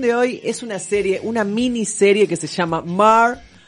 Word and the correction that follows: Mare: Ya empecé mal. Mer. Se escribe Mare, chicos.